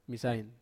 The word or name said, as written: masain